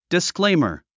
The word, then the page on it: disclaimer: diskléimə r; ディスクレイマァ